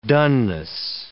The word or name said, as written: dunness